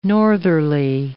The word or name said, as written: northerly